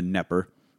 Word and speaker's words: Knepper: Silent K, if you're trying to look him up at home, on the Knepper.